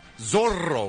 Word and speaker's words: Zorro: Zorro, Zorro. You have to roll the R's.